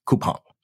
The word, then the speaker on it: Coupang: I think we should pronounce it Coupang, you know, or Coupang, but I was corrected to pronounce it Coupang.